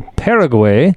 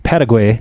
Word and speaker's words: Paraguay: beaten Paraguay Ooh. October. I'm sorry, I got to say that right. Paraguay. Paraguay on uh, October 1st, six to one in that game.